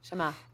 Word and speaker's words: Shema: When you were telling me about Shema, am I saying it right? Shema? – Shema.